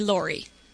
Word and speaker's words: Lori: Lorraine, but everyone calls me Lori. – Can you spell it for me? Just spell Lori. – L-O-R-I. – L-O-R-I, okay.